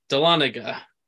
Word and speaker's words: Dahlonega: Dalwunga. – Dalwunga. – Dahlonega. – Crap. Dahlonega. Wow. Dahlonega. It's spelled D-A-H.